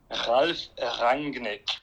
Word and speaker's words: Rangnick: German words, German names are pretty much pronounced exactly as they're spelled. So the second part of that name, Rangnick, technically speaking. In reality, certain dialects, it goes into a bit of a Rangnick, a bit of a softer G.